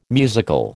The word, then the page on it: musical: American English